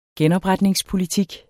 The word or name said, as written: genopretningspolitik